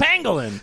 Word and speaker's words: pangolin: It's a pangolin. – It sounds like penguin. – It's not penguin, but it's pangolin.